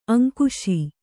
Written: ಅಂಕಿಸು

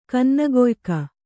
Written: ಕನ್ನಗೊಯ್ಕ

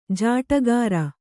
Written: ಜೋಡಗಿರಿ

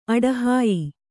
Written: ಅಡಹಾಯ್

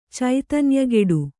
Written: ಚೈತನ್ಯಗೆಡು

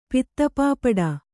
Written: ಪಿತ್ತಪಾಪಟ

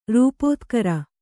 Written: ರೂಪೋತ್ಕರ

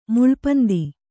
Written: ಮುಳ್ಪಂದಿ

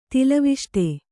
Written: ತಿಲವಿಷ್ಟೆ

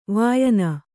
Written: ವಾಯನ

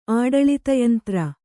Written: ಆಡಳಿತಯಂತ್ರ

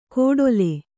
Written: ಕೋಡೊಲೆ

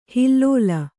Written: ಹಿಲ್ಲೋಲ

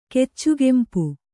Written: ಕೆಚ್ಚುಗೆಂಪು

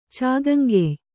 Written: ಚಾಗಂಗೆಯ್

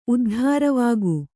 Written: ಉದ್ಧಾರವಾಗು

